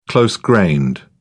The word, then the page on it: close-grained: British English